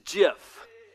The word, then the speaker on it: GIF: The correct way to pronounce that word, according to the dude that invented it, is GIF. GIF with a J, like the peanut butter.